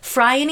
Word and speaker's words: Phryne: Her name is Phryne. That's spelled P-H-R-Y-N-E. Pronounced Phryne, and she modeled for the first life-size depiction of the naked female form in Greek history, and the marble sculpture of Aphrodite.